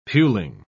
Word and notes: puling: British English

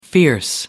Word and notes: fierce: American English

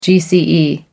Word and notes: gce: American English

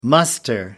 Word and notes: muster: American English

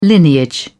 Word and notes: lineage: British English

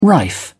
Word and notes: rife: British English

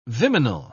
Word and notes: viminal: British English

